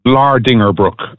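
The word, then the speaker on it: Vlaardingerbroek: Yeah, firstly, Vlaar Dingerbrook is how you pronounce her name. I asked her myself on one of the occasions I met her, because like you, I always got it wrong.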